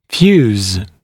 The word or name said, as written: fuse